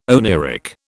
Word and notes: oneiric: British English

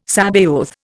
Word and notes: Sabaoth: Indian

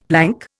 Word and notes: plank: Indian

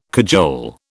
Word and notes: cajole: British English

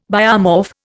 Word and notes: biomorph: Indian

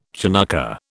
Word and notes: Chanukkah: British English

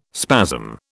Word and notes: spasm: British English

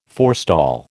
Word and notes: forestall: American English

